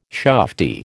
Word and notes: shufti: American English

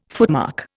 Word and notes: footmark: Indian

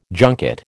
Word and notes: junket: American English

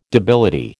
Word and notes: debility: American English

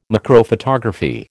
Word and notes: macrophotography: American English

